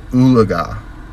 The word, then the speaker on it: Oologah: Ooliga, Oklahoma. You heard me right, Ooliga. It's spelled O-O-L-O-G-A-H.